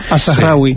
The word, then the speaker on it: Al-Zahrawi: Pues mira, el primero que he traído es Al-Zahrawi, ya me dirá a mí que él si lo he pronunciado bien o mal. – Al-Zahrawi. Sí. – Perfecto, pues lo he pronunciado bien. Mal. Es una buena manera de no decírmelo abiertamente. – Sí, no, bien, bien.